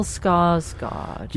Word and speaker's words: Skarsgård: Now, is it Bill Skarsgård? – Yeah.